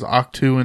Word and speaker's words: Ahch-To: And it looks like it's a planet called Ahch-To, spelled Ahch-To. I'm pronouncing it as Ahch-To until I hear something different.